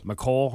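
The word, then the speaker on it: Mecole: McCole Hardman.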